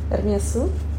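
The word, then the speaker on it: Hermès: Да, только там S не произносится, произносится. Потому что это французское слово. Хочешь сейчас ход конем, просто на который делится жизнь до и после? Это его фамилия, и сам а, его там представитель главный есть интервью, в котором озвучивается вслух его фамилия с «С». А по правильно по правилам, да, мы «С» не читаем, но так как это фамилия, она там читается у него. Это ну, Эрмес, да.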